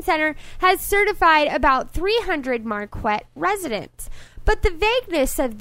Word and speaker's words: Marquette: It's pronounced Marquette.